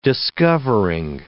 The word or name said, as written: discovering